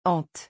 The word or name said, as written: ante